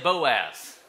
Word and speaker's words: Boaz: Everybody say Boaz. It's spelled B-O-A-Z.